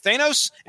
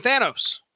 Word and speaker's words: Thanos: And Thanos is going to be most likely pronounced two different ways tonight. Thanos and Thanos. So just get used to it.